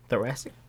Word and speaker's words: thoracic: He's out with, how do you say this? – Thoracic? – Yeah, thoracic.